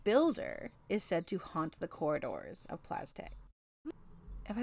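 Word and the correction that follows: Plas Teg: It's Plazteg.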